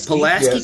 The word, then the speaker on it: Pulaski: I want to say that's Pulaski. Am I correct on that one? There you go. – Pulaski yes.